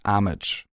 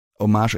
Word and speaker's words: Homage: And yeah, I know I have listened to another reading of this story, and in this chapter particularly, that other narrator pronounced her name as Amage. And I looked it up. It is written H-O-M-A-G-E, and that's homage.